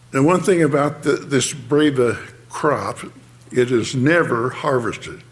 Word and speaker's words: Breba: The modern name is, Breva, B-R-E-B-A. It looks like it should be Breva, but it's pronounced Breva.